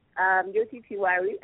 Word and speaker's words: Jyoti Tiwari: If you're just tuning in, I've been speaking with my special guest all the way, live all the way from India, Jyoti Tiwari.